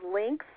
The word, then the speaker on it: Lynx: So, is is links like more than one, or is it link? – No, it's links. L Y N X, like the cat.